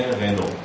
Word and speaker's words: رنو: نوشته رنالت ولی خودشون هم میگن رنو مثلا خب بعد اینا چیه؟